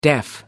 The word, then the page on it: deaf: American English